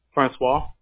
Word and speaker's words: Francois: Am I pronouncing your name correctly? – Francois. – Francois. Okay, should have known the French.